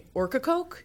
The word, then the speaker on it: Ocracoke: Ocracoke. – Sorry, guys. – Although Ocracoke would make sense because isn't orca a type of whale? Do they have those off Ocracoke? – I don't know.